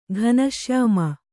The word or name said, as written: ಘನಶ್ಯಾಮ